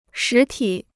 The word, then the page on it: 实体: shí tǐ